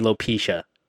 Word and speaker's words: alopecia: Apparently, no, the correct way to say it is. Wait, did I say it right? – Alopecia. – So I just said it right just now. The correct way to say it is alopecia.